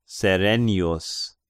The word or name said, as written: Serennius